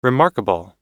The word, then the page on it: remarkable: American English